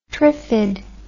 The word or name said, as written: triffid